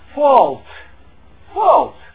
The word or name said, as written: fault